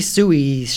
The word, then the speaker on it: sous: Next for me is pastry sous chef. The word sous comes from French for under, or pastry, sous, I don't know how to pronounce this, S-O-U-S. – Sous. – Sous? – Sous chef, right? – Oh, yeah.